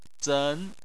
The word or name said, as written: zen2